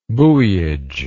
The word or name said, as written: buoyage